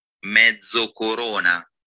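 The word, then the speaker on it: Mezzocorona: A Pinot Grigio from we're lucky enough to have one of our HBP's research assistants is Italian, so he's going to tell us how to pronounce his place, and it's pronounced Mezzo Corona. – Thank you. So I'm having a Pinot Grigio from Mezza Corona in Mezza Corona, Italy, and it's on the north coast of Italy.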